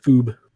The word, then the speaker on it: Foob: Like that, Foob. – I think that's how you say her name. – Foob. Yeah, that's how you say it, Foob.